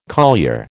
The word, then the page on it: collier: American English